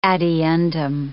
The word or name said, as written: ad eundem